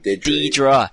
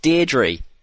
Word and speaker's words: Deirdre: Hey, Deirdre. Lucky Charms. – I'm pretty sure it's Deidre. – No, it's not Deidre. It's Deirdre.